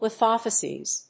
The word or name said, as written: lithofacies